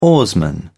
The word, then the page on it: oarsman: British English